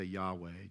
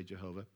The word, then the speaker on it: Yahweh: It's transliterated as YHWH. We add the consonants. Hebrew doesn't have consonants. Make it pronounceable. So we say Yahweh. Jehovah's Witnesses insist that you say Jehovah.